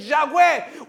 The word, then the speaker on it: Yahweh: Mire, y cuando uno lo pronuncia correctamente, Yahweh, uno está respirando.